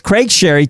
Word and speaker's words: Craig Sharry: Hey, coming up, we've got returning guest Craig Sherry, and that, by the way, is spelled S-H-A-R-R-Y, because when you hear it, you think the Sherry, the wine Sherry thing, right? – Yeah. – But it's Craig Sherry,